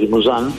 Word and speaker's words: Limousin: O sea que la raza Limousin, vamos a pronunciar como corresponde, ¿no? Porque tengo que estudiar un poquito todo. Es una raza de origen francés, Limousin.